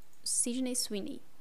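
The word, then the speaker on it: Sydney Sweeney: Sidney Sweeney, é assim que pronuncia.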